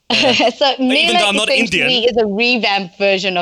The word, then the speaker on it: Mela: Did I pronounce that correctly? Mela? – Correct, that's right, yes.